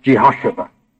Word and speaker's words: Jehosheba: And her name was Jehosheba.